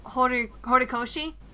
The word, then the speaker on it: Horikoshi: Like, I think there's even a the creator, the mangaka, Kohei Hiro. Forgive me, I'm going to butcher this name. It's Kohei. Let me see. Kohei Horikoshi.